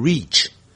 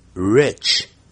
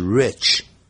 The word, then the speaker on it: rich: The soil is rich, rich, it, 二三四 it, 不要念成 reach, rich, rich, it 顶不是衣服的衣，不是 reach, 而是 rich.